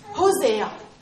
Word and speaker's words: Hosea: In Hosea, how do you say that? – Hosea. – Mm. Yes, got it? – Yeah.